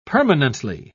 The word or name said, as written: permanently